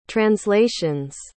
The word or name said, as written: translations